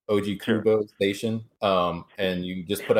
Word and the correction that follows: Ogikubo: It's actually pronounced Ogikubo Station.